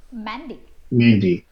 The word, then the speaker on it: Mende: And then in the east, they speak a language called Mende. – Mende, yes. It's M-E-N-D-E. – Beautiful. – It's spelled Mende. It's called Mende.